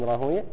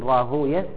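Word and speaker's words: راهويه: علقوا عليه، يقول إسحاق، اكتب في الحاشية إسحاق، هو الإمام إسحاق ابن راهوية، إسحاق ابن إبراهيم ابن راهوية، ويقال أيضا راهوي، تنطق هذا وهذا. المحدثون يقولون راهوية والنحاة يقولون راهوي في الأسماء المختومة بوي، المحدثون ينطقونها راهوية والنحاة ينطقونها راهوي.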